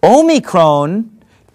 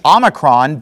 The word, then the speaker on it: Omicron: Omicron pronounced in English Omicron, but in Greek it's pronounced Omicron, okay?